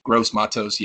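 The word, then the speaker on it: Gross-Matos: It's Gross Matos. – Gross Matos, yeah.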